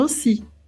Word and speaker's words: aussi: Se escreve A-U-S-S-I, mas a gente fala aussi.